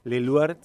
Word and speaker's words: Leloir: Le tengo mucho aprecio a Complejo Leluar, eh, allí en Salta 2045, eh, colegio e instituto. Su página web es institutoleluar.edu.ar. Leluar se escribe Leloir con I latina institutoleloir.edu.ar, secretaría arroba es la dirección de emails para recabar información, enviar eh, alguna documentación.